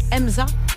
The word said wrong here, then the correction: Hamza: Hamza, Gazo et Guy de Besbar.